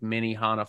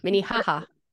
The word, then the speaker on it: Minnehaha: We did do that like Minnehaha Falls. Did I say that right? – No. Minnehaha. – Oh, sorry. Yeah, there's no end.